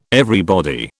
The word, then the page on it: everybody: British English